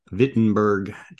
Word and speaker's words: Wittenberg: A year after Charles V became king of Spain, Martin Luther nailed his 95 theses to the Wittenberg church door. – Oh, good pronunciation. Thank you. – Yes. Well, I did live in Germany for 3 years.